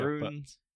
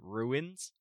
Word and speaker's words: runes: Hexes, runes. I mean, so yeah, TV show, runes. But... I know someone who pronounces it ruins. – They're wrong.